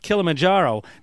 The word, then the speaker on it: Kilimanjaro: I finally learned how to say it, Mount Kilimanjaro. Not that easy once you see it.